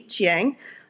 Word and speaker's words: Chiang: Chiang.